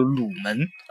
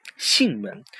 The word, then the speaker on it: 囟门: 那么第二个的话呢，我们除了望头颅的形状，那么还需要去望囟门。那么囟门的话呢，我们头部囟门分两个，分为前囟门和后囟门。啊，之前的话呢，在嗯上学的这个时候，好多人读成了鲁门啊，叫前鲁门和后鲁门啊，其实念囟囟门。